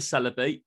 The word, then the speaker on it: Celebi: You know, I like I like the Celebi.